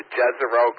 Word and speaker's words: Jezero: Okay, well, JZ would be for Jezero Crater.